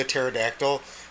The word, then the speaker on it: pterodactyl: And Starfire chooses the pterodactyl. And she's like, I know the P is silent, but I like to say it, so it's my pterodactyl.